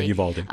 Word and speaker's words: Uvalde: Yeah, Uvalde.